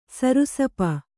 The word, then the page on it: ಸರ್ಷಪ: Kannada